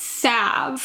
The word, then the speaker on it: salve: Apparently, it's salve. – Salve. – But it's spelled, for those who aren't familiar with the word, maybe, S-A-L-V-E. – Right. I've always said salve.